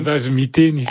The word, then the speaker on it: Vasomiteni: corrigez ma prononciation, des Vasomiteni, comment on dit ? – Des Vasomiteni, ça veut dire...